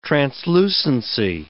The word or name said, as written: translucency